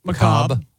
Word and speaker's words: macabre: Macabre. Yeah, the R-E silent or whatever.